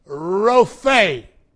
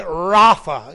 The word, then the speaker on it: Rophe: This is the great title for God in the Old Testament, the Hebrew title, Jehovah Rophe. Some are pronouncing it Rapha in our day. Several groups go by that name, Rapha.